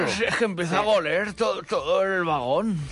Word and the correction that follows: oler: Bueno, pero pueden hablar bien, no se dice goler. – Sí, bueno, déjalo. – Se dice oler.